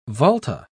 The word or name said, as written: volta